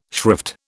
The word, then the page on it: shrift: British English